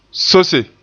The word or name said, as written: sose